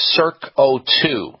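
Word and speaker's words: CircO2: an oral supplement called CirqO2. Circ for circulation, O2 for oxygen, and it's spelled C-I-R-C and then O2. Yeah, I would look it up on the internet.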